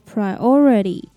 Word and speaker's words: priority: 并且把重音放在第二个音节念作 priority